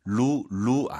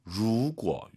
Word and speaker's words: 如果: 루 아니에요. R 발음 더 말아야 됩니다. 루고 이거 기억하시고요.